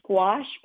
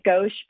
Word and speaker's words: gouache: It's actually pronounced gouache, but I have a habit of pronouncing it gauche because I think it sounds more French.